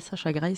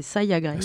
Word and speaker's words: Saya Gray: C'est pas Sasha Gray, c'est Saya Gray.